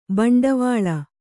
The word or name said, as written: ಭಿಂಡಿವಾಲ